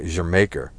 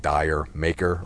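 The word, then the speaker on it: D'yer Mak'er: Jermaker. Some people call it Dire Maker or Dire Mocker. Jermaker. It's some kind of like Welsh spelling and pronunciation of the island of Jamaica.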